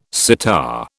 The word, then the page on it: sitar: British English